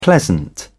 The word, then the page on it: pleasant: British English